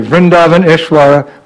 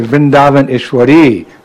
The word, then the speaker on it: Vrindavaneshwari: We don't say Vrindavaneshwara, we say Vrindavaneshwari, Radhe, Radhe.